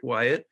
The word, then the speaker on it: Wyett: But my name is Patrick Wyatt, and you spell that W-Y-E-T-T.